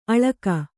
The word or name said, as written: ಅಳಕ